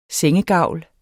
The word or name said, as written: sengegavl